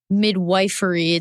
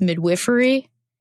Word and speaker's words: midwifery: And you called it midwifery. So a midwife practices not midwifery, it's midwifery. – Mm-hmm. Yeah, it's spelled midwifery though.